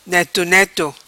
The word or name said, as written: Neto Neto